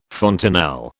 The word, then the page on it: fontanelle: British English